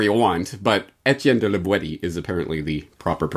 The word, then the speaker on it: La Boétie: Properly pronounced not, as might be thought, La Boétie, but rather La Boétie, with the hard T, as it was pronounced in the Périgord dialect of the region in which La Boétie lived.